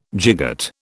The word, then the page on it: gigot: British English